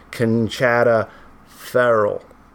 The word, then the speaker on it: Conchata Ferrell: I was like, "This is how you pronounce Conchata Ferrell's name," and then I pronounced it Conchata Farrell. In reality, I'm just like laying it down right now hard. Her name is pronounced Conchata Ferrell.